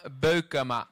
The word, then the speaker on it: Beukema: Il cognome non lo so, dillo una volta per tutti, come si pronuncia? – Sam Beukema. – Beukema, se lo volete, bravo, dire, bravo.